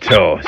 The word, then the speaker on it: Toa: Well, my name's Graham Toe, T-O-A, but it's pronounced Toe.